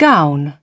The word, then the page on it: gown: British English